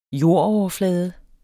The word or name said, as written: jordoverflade